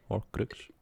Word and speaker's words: Horcrux: Zo spreek je dat uit, toch? Horcrux. Ja, zo.